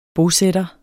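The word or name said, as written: bosætter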